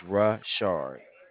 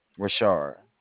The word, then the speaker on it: Rashard: It's Rashard. Rashard. – Richard. – Yes, Rush is Rashard. Shard.